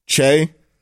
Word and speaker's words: Che: Now, maybe I should have, in parentheses, pronounced Che.